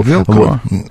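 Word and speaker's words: Velcro: Велкро.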